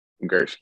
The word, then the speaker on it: Gurske: Gurske, yeah.